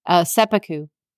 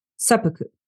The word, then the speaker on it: Seppuku: Oh, can I even pronounce that word? Seppuku? – Seppuku. – Seppuku.